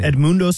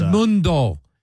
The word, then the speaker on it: Edmundo: Edmundo, how you pronounce that first name? Sosa. What's that first name? – Edmundo Sosa. – Edmundo.